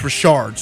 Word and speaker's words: Richard: Yeah, he tweeted the pronouncer that it's Richard, so now everyone felt like you, the Dick in the situation, that was pronouncing it Dick, but it's really Richard. – Gosh, I'm so glad we clarified that. – Yeah, so now everybody knows it's Chris Richard. It's not Chris Dick.